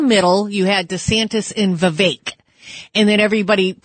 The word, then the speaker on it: Vivek: Yes, can I just say his name is Vivek Like Cake.